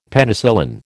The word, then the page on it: Penicillin: American English